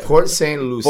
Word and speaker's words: Port St. Lucie: Oh, Port St. That's how you pronounce it, right? Excuse my pronunciation. – No, it's okay. Yeah. Port St. Lucie.